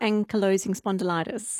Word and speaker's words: ankylosing spondylitis: Ankylosing spondylitis.